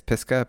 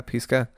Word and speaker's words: Pisgah: I think that's how you say it. It's Pisgah. – Pisgah.